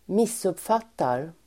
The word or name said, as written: missuppfattar